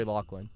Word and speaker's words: Loughlin: Lori Loughlin.